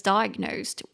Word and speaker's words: diagnose: 所以被诊断为就是 somebody is diagnosed with something。 Somebody is diagnosed with something。那个诊断那个字啊，超难念的，它念作 diagnose，diagnose，三个音节，die d i a g a g nose n o s e。